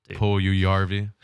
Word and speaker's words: Puljujarvi: Oh, is that an extra little syllable there? Yeah, it is Finnish. – Yes, say Puljujarvi.